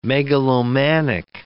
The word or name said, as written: megalomanic